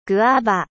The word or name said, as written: goyave